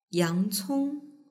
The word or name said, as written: yangcong